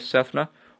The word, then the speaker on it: Sethna: You can find my Twitter account by just typing in @NordoniaSports or my name, Darayus Sethna. That's DarayusSethna. If you can spell it, I'll be impressed. If you can pronounce it, I'll be even more impressed. That's Darayus Sefna.